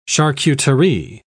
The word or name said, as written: charcuterie